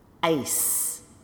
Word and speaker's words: ice: The first word are the eyes that we see with, and that's a Z sound at the end, versus ice, which is something cold you put into a drink, and that's a final S sound.